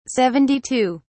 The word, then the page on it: 72: British English